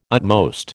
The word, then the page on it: utmost: American English